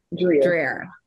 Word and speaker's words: Dreher: Dreher.